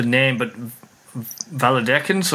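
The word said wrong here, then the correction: Viladecans: or is that right? Valladecans? – Villa de Cans.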